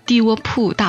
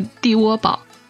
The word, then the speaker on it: 地窝堡: 作者尤斌是新疆人，当时我还把他笔下的地窝铺大盘鸡读成了地窝堡。